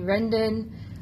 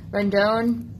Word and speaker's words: Rendon: Anthony Rendon, Rendon. There you go.